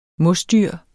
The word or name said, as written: MOSDYR